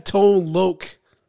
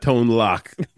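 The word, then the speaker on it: Tone Loc: We have Tone Loke. That's how you say it, right? – Yes, not Tone Lock.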